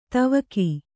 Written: ಧಾವಕ

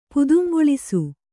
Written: ಪುದುಂಗೊಳಿಸು

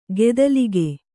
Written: ಖತಿಲಕ

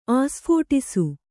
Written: ಆಸ್ಫೋಟಿಸು

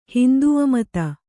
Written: ಹಿಂದೂಮತ

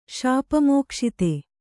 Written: ಶಾಪಮೋಕ್ಷಿತ

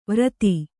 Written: ವೃತ್ತಿ

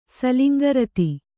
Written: ಸಲಿಂಗರತಿ

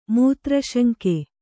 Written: ಮೂತ್ರಶಂಕೆ

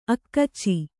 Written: ಅಕಚ

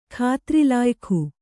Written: ಖಾತ್ರಿಲಾಯಖು

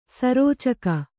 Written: ಸರೋಚಕ

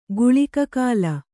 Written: ಗುಳಿಕಕಾಲ

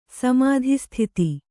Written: ಸಮಾಧಿಸ್ಥಿತಿ

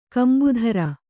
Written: ಕಂಬುಧರ

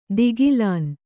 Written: ಧಿಗಿಲನೆ

